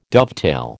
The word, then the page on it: dovetail: American English